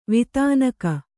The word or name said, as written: ವೇಧನಿಕೆ